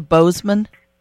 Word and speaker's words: Bozeman: And joining us to explain a little bit more about that is Rena Walsh, talking to us from, is it pronounced Bozeman, Montana, or Bozeman? – That it is. – But the locals pronounce it Bozeman? – That's right.